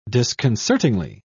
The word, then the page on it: disconcertingly: British English